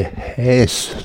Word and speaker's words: Häs: Ja, der Name Häs, so spricht man es eigentlich ganz genau aus, habe ich mir sagen lassen, kommt aus dem Vorarlbergischen.